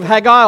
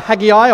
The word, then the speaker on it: Haggai: We're in the book of Haggai or Haggai or Haggai, depending on which way you pronounce it. All are correct.